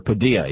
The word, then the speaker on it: Padilla: citizens, we thought it was just Jose Padilla, or Padilla, he wants it to be pronounced now, and Yasser Hamdi.